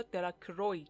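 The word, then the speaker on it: Delacroix: بابی و هری جونز و دیکی دلاکرا، اهالی دهکده اسم او را دلاکروی تلفظ می‌کردند، در یک گوشه میدان تل بزرگی از سنگ درست کردند و مراقب ایستادند که پسرهای دیگه به اون دستبرد نزنند.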